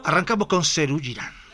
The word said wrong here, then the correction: Serú Girán: Perdón, lo pronuncié como Cirila, Serú Girán, dije. Serú Girán.